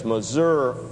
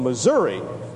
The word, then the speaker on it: Missouri: And whenever we see a Missouri, that's how they say it there, it's Missouri, not Missouri.